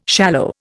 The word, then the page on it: shallow: Indian